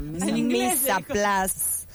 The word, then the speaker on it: mise en place: A ver, pero el término mise en place, pronunciación francesa, mise en place, traducción literal que significa preparando al uso, o literalmente preparación, organización previa.